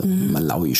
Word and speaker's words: Malawi: Also Malawi spricht man es eigentlich aus.